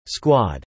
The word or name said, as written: squad